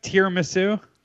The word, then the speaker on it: tiramisu: Tiramisu? – Yeah.